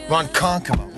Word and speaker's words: Ronkonkoma: How do we say that? – Ronkonkoma. – We're in Ronkonkoma.